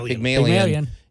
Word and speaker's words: Pygmalion: You've got The Companion, which is a very modern story written 4 years ago, paired up with Pygmalion. Did I say it right? – Pygmalion.